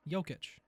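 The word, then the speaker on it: Jokić: Jokic, that's a fine. – How do you say his name? – Jokic.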